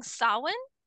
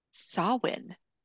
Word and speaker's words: Samhain: It's spelled Samhain. It's saying Samhain. – Samhain.